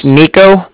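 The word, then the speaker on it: Niko: All right, so my name's Nico. That's N-I-K-O.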